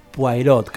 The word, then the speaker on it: Poirot: Em, está basado en un mítico libro de Agatha Christie eh, y por supuesto protagonizado por el detective Hércules Poirot, creo que se pronuncia. – Se escribe Poirot, pero creo que Poirot y Poirot, sin la T, creo.